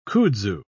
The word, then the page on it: kudzu: British English